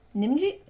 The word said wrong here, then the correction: Najimi: I don't know how to pronounce that. – Let me see. – Najimi? – Oh, okay. Kathy Najimi.